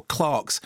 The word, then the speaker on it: Clerks: I always worry about how to pronounce the title of that. You know, the Kevin Smith film, Clarks, Clerks. – Don't they say Clerks? We would say Clarks, but don't they say Clerks in the movie? – Yeah, exactly.